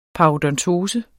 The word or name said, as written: paradentose